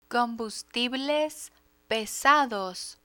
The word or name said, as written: combustibles pesados